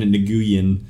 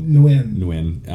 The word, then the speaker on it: Nguyen: Diane Nguyen. I know I said that wrong. – Nguyen.